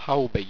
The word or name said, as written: haubby